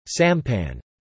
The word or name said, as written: sampan